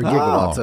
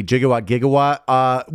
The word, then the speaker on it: gigawatt: Also, I did look into the gigawatt. We changed the pronunciation. It used to be a gigawatt, but we switched to the soft G gigawatt, and that is what we currently use.